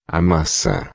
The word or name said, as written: maca